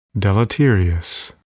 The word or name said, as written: deleterious